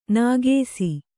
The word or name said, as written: ನಾಗೇಸಿ